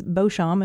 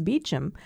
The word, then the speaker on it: Beauchamp: They share a last name, but they pronounce it differently. One is Beauchamp and one is Beauchamp.